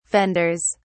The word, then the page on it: fenders: British English